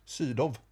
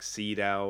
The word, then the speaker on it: Sydow: Max von Sydow. – No. You heard it here first, ladies and gentlemen. That's how you pronounce the name. Because in English, people will say like Sydow or whatever, you know.